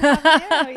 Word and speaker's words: Habanero: Habanero, yep.